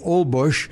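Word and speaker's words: Holbox: It looks like whole box, but it's spelled Olbush.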